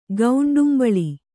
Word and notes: ಗೌಂಡುಂಬಳಿ: Kannada

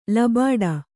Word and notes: ಲಬಾಡಿ: Kannada

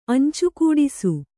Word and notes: ಅಂಚುಕೂಡಿಸು: añcukūdisu

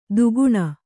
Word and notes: ತಗಣ: tagaṇa

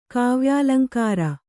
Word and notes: ಕಾವ್ಯಾಲಂಕಾರ: kāvyālaŋkāra

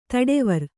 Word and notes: ತಡವರ್: taḍavar

